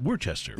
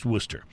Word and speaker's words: Worcester: Worcester is spelled W-O-R-C-H-E-S-T-E-R. So if you live somewhere other than Massachusetts, you'd be tempted to call it Worcester, right? – Yeah. – Well, it's pronounced Worcester. And there's also a city in the greater Boston area called Dorchester, D-O-R-C-H-E-S-T-E-R.